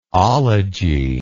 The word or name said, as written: ology